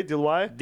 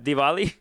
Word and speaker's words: Diwali: How do you say Diwali? – Diwali. – Diwali, yeah.